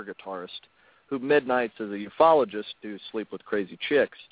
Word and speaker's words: ufologist: Is it UFologist? Is that how it's pronounced? It's the ufologist. The ufologist. Okay, okay, okay.